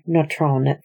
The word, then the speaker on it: Nontron: a type of clay mineral found near Nontron, France, which is why I've been pronouncing everything slightly French, with, of course, my terrible American accent.